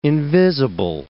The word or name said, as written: invisible